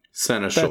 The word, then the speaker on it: seneschal: Caradoc was known as the, the Satchel of Lord Soth. – Satchel? – I may have said it wrong. Sight. – Seneschal.